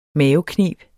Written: mavekneb

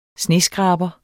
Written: sneskraber

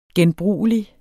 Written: genbrugelig

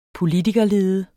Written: POLITIKERLEDE

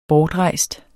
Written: bortrejst

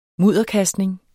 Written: MUDDERKASTNING

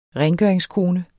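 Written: RENGØRINGSKONE

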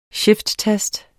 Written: shifttast

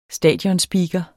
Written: stadionspeaker

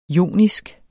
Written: JONISK

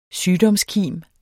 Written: SYGDOMSKIMS

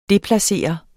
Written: DEPLACERE

